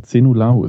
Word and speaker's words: Zenulahu: Errol und dann Zenulahu ist wie 10-0. – Ahu.